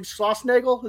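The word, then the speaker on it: Schlossnagle: And your old pal, Jim Schlossnagel, is that how you pronounce it? – Yep.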